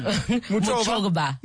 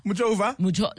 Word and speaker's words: Muchova: Muchova. – Muchova.